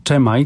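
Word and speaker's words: Chi Mai: taką składankę z muzyką Ennio Morricone i tutaj przy właśnie Czemaj, tak jak mówisz, tak i tak powinno się wymawiać.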